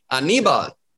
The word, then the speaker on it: Aníbal: I was like, where is the emphasis? It's on the knee, Aníbal.